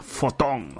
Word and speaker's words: Fotón: Los van a, los va a crear Wanda, así como han dado poder a Fotón.